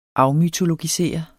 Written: afmytologisere

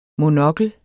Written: MONOKLERNE

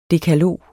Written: dekalog

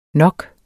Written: nok

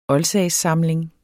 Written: OLDSAGSSAMLING

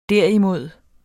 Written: derimod

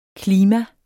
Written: klima

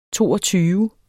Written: toogtyve

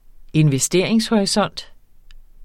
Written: investeringshorisont

